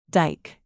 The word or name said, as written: dyke